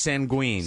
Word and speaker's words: sanguine: And it's pronounced sanguine. – Sanguine. – Is it I have sanguine. Which sanguine. – Is S-A, big N, big G, G-W-I-N is the phonetic spelling.